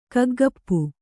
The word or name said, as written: ಕಗ್ಗಪ್ಪು